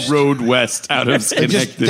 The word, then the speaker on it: Schenectady: So there's no road west out of, I don't know how to say this, but Schenectady. – Schenectady. – Schenectady. Yeah. Schenectady.